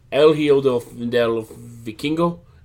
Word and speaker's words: El Hijo del Vikingo: El Hijo del Vikingo.